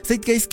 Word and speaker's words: Zeitgeist: La primera película de la que quiero platicar es Zeitgeist, pero se escribe Z-E-I-T-G-E-I-S-T.